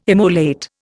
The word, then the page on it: immolate: Indian